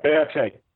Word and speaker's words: Beate: Okay, so how do you take a story like Beate? Is that how it's pronounced? – Yeah, Beate, yes.